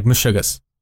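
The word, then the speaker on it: meshugas: Say like mishugas. Like mishugas. Mishugana? In mishugas. And then pronounce it like mishugas.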